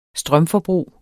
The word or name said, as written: strømforbrug